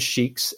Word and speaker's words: Sheeks: And that is, so my last name is Sheiks, S-H-E-E-K-S, and then Freaks, F-R-E-A-K-S.